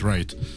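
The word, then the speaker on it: Wright: В Райт пишется с W, да? Звучит Райт.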